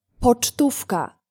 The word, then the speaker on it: pocztówka: Postcard in Polish is pocztówka.